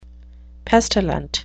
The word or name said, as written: pestilent